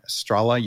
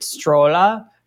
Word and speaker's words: Stråla: she's pronounced it Strola.